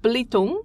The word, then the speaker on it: Belitung: So it's pronounced Blitung. – Blitung. – But it's spelt Belitung, and often that is how it's pronounced.